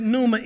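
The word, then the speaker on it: pneuma (πνεῦμα): And then when you get to the New Testament or the Greek, the word is pneuma, pneuma. The P is silent. The word is pneuma.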